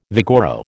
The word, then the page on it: vigoro: American English